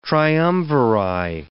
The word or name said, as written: triumviri